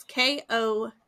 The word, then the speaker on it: Ko-fi: Because it's like, buy me a coffee, but it's K-O-F-I, Ko-fi, which they said rhymes with no fee, because it's like no fees, but like PayPal took a fee when I put it into my PayPal account.